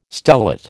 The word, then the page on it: stellate: American English